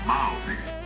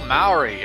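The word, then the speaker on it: Māori: Maori. – Oh, Maori.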